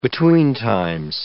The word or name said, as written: betweentimes